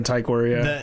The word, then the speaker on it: Tykwer: Tykwer. Yeah. Yeah, sorry. – Yeah, that's why we were thinking Tykwer, yeah. – The I in sit is how you say the Y.